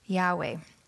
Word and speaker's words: Yahweh: But there are many reasons why biblical scholars are confident that it should, in fact, be pronounced Yahweh.